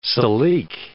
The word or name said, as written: silique